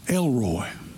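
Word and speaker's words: El Roi: It's a Hebrew name and it's L-E-L and then capital R-O-I. Now that's pronounced Elroy.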